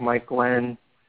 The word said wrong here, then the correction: Glennon: And Mike Glennon, excuse me, I almost got through a whole show without mispronouncing his almost.